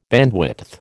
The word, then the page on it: bandwidth: American English